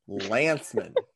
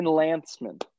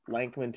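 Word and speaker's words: Lancman: Well, so here's the thing. It's L-A-N-C-M-A-N. Yep. Yep. And I have edited I have edited an absurd number of his blogs, and I have called him Lankman to his face via the group chat, and nobody's ever questioned me on it until today.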